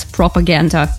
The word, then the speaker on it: PRPGND: Yeah, it's written as PRPGND, but yeah, it's pronounced as propaganda.